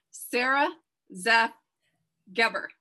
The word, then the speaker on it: Sara Zeff Geber: Today, my guest, let's see if I can pronounce this correctly, Sara Zeff Geber.